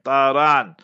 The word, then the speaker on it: Tharan: His son's name, according to historical sources, was Taran. In English, they spell it as T-H-A-R-A-N, Taran.